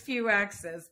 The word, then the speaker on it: Fuiaxis: Close enough. It's Fuiaxis, but it's okay.